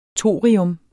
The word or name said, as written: thorium